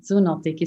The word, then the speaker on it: Zoonotic: But now your third book, Zoonotic. Do I pronounce that correctly? – Yes, exactly. Zoonotic is perfect.